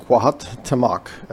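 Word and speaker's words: Cuauhtémoc: I think it's pronounced Cuauhtemoc, uh, C U A U H T E M O C.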